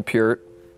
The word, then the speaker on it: Peart: I grew up a drummer, so Neil Peart, that's how it's pronounced.